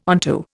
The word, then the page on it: onto: Indian